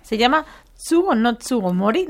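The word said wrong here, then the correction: Tsuru no Sugomori: No sé si lo he pronunciado bien. – Tsuru no Tsugomori.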